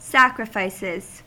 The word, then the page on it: sacrifices: American English